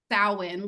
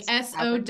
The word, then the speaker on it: Samhain: It's supposed to be Samhain. Because it's Celtic. – That's so different. – It's not. It's so wrong. – Say it again. – Samhain. Like Sowin. Samhain.